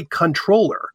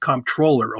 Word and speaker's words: comptroller: The other one was comptroller. It's really controller. But when these pedants in the 1500s and 1600s decided to go backwards to the origins of the word, they put the P back in. And controller, like in a business thing, is not pronounced comptroller, although it a lot of times is spelled C-O-M-P.